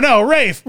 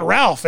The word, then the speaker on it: Ralph: And it's, no, Rafe. Rafe. It's not Ralph, is it? – I think it's Ralph. – He doesn't pronounce it Ralph because he's French, right? – Ray.